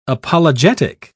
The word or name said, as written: apologetic